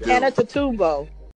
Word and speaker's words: Antetokounmpo: That Antetokounmpo.